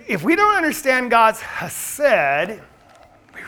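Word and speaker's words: chesed (חסד): And so the best way they understand that they pronounce it, it's a guttural, like the German hard k, like ich. It's chesed. Now, that's hard for our English tongue to say, chesed.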